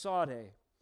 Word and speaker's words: Tsadhe: The word, as far as I know, is pronounced Sade, T-S-A-D-H-E, and that is the 18th letter in the Hebrew alphabet.